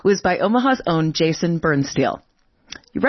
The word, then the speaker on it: Birnstihl: That is Jason Bernsteel. It's spelled B-I-R-N-S-T-I-H-L.